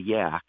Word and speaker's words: Yaak: There's a place in Montana called a yak, and it's spelled Y A A K and it's just so remote and it's it's pristine.